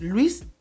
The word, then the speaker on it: Luis: En el acento japonés no, porque mi nombre en el acento japonés me lo dijeron cuando era niño y mi nombre es Luis y se pronuncia algo así como Ruizu.